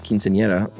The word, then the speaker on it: quinceañera: Oh, and your pronunciation of quinceañera was great. – Nice. – As the E-R-A is a soft R sound.